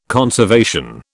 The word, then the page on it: conservation: British English